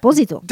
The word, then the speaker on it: Esposito: Giancarlo Esposito, which I found out that's how you're supposed to say his name. – Oh, not Esposito? – It's Esposito.